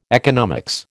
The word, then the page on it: economics: American English